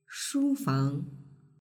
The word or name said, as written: shufang